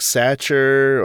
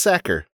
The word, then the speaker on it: Sachar: Mm, I always wondered how to pronounce his name if it was like Satcher or Sachar or what the what the pronunciation of of it was, but I never looked it up until recently. Mm. But it's Sacker. Yeah. Never would have guessed. Rhymes with cracker.